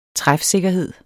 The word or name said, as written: træfsikkerhed